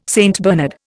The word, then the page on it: St Bernard: Indian